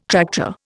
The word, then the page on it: tractor: Indian